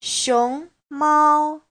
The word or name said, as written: xiong2mao1